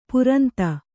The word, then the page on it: ಪುರಂತ: puranta